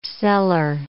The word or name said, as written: cellar